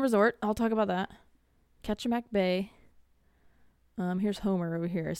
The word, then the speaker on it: Kachemak: And the Homer Spit is just like it's like 4.5 mile jut of land out into the Kachemak Bay. I looked up, there's a couple different ways they say to pronounce it, but I think it's Kachemak. Homer Spit. – Yeah, I think there's a Kachemak National Park, Kachemak area up there with a bunch of bears.